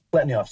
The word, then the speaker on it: Pletnev: Pletnyov. So Mikhail Pletnyov. He's spelled P-L-E-T-N-E-V, Pletnev, but it's pronounced Pletnyov.